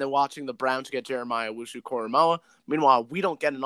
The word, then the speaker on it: Jeremiah Owusu-Koramoah: Jeremiah Owusu-Koramoah. I'm going to continue to flex the fact that I know how to pronounce it. – Oh, boy. That's why I go with the solid J-O-K.